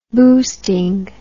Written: boosting

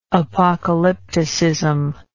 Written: apocalyptician

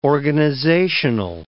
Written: organizational

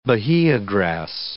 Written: Bahia grass

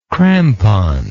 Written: crampon

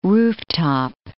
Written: rooftop

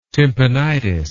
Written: tympanitis

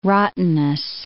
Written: rottenness

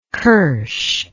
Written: qursh

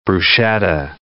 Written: bruschetta

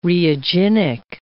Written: reaginic

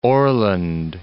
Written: Orland Park

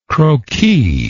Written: croquis

